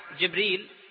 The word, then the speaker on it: جبريل: جبريل نطقها عند العلماء على نوعين: جبريل وجبرائيل.